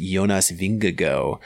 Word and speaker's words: Jonas Vingegaard: For all those people reading it in American English, actually it's pronounced Jonas Vingegaard.